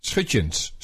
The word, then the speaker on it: Schutjens: S-C-H-U-T-J-E-N-S.